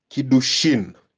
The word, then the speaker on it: Kiddushin: In those days, there was a Jewish custom called Kiddushin. Kiddushin. Kiddushin is spelled K-I-D-D-U-S-H-I-N. K-I-D-D-U-S-H-I-N.